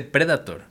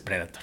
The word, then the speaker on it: Predator: Y eso es cuando dije, ah, órale, se dice Predator. Y ya, entonces dije, ah, pues es Predator, ¿no? – Sí, sí.